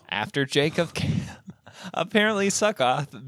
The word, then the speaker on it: Sukkoth: Yeah, I was about to say, Sukkoth sounds right.